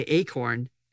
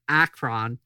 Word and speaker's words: Akron: I keep wanting to say Acorn. And I'm auto-correcting myself in my brain to Akron. Oh, sorry. What does it say? – Akron. – Akron. It's spelled Akron.